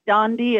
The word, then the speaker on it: Dondi: Dondi Dahlin or Dondi Dahlin. Everybody says it different. It's a Swedish name, so it's almost never said correctly, but Dondi is perfect.